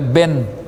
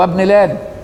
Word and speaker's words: ابن: مش تعمل بقى زي الجماعة اليمنيين يقولك بن عمر بن الخطاب بن لادن، لا هو ابن لادن، واخد بالك ليه؟ لان تنطق بالاجماع لكن لا تثبت خطا الا في اول السطر او ان كان منسوب لغير ابيه او منسوب لامه.